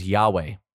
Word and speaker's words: Yahweh: While the ancient pronunciation of this word is lost, you will most often hear this name pronounced as Yahweh.